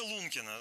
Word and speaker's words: Лункина: Деревня Лункина. Вообще, правильно ли я ставлю ударение вообще, сразу возникает вопрос? – Да, конечно. В целом, мне кажется, что такое место и говорящее название, что его прям... сложно ошибиться, но вы все правильно сказали.